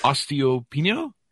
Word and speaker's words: osteopenia: osteopenia. Did I pronounce that right? – Probably bone loss. – I'm still working on that, aren't I? – Osteopenia. – Osteopenia, there you go, and chronic infections.